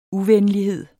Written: uvenlighed

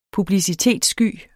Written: publicitetssky

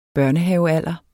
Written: børnehavealderen

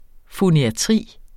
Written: foniatri

